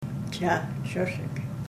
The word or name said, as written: chahchesek